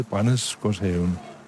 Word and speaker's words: Brændesgårdshaven: Prøv lige at sige det igen. – Brændesgårdshaven. – Brændeskovshaven. – Ja. – Ej, det er ikke godt. Må hjem og øve mig.